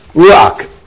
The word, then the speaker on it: ruach: 啊，那我们上次讲的这个 spirit 这个字也是由这个 Furtive pataf, ruach, now, this furtive pataf, 它的发音还记得我上次提到吗？不是如哈，不是，你的发音不是 R O H A，不是如哈。他碰到这个 furtive patal，他是把这个母音呢放在前面的如啊，一变成如啊，是这样的味道。